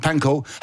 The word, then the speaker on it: Pankow: Pankow? – Pankow.